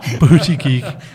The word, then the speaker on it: Buttigieg: Booty Geek. Ja. Booty Geek. – Pete Buttigieg.